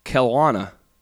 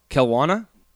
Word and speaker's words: Kelowna: Like you're saying clown. Like Kelowna. – Kelowna.